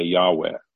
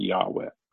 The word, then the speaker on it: Yahweh: It's thought to be, it's difficult to know how to pronounce it, because the vowels were not preserved. So today you'll hear Pastor Ken sometimes, he'll say Yahweh. You'll hear him talk about Yahweh from the pulpit sometime.